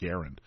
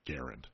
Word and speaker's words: Garand: And he said, "Well, I learned that actually the family they don't pronounce it Grand, they actually pronounce it Garand." So we were going to make a shirt that, you know, Gun Owners Radio on the front, on the back says actually it's pronounced Garand.